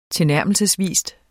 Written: tilnærmelsesvis

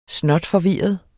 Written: snotforvirret

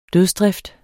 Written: dødsdrift

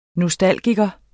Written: nostalgiker

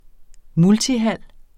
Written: multihal

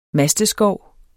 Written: masteskov

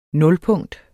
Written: nulpunkt

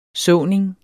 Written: såning

